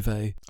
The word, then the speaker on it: Weil: So who is Simone Weil? Have I pronounced that right? – Well, I think people pronounce Weil in lots of different Weil or Weil, you know, whatever, so, but I say Weil.